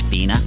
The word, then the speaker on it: Fine: It's spelt fine, but pronounced Fina.